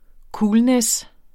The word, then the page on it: coolness: ˈkuːlnεs